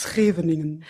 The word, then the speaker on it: Scheveningen: Scheveningen.